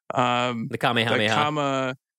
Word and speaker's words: Kamehameha: The Kamehameha.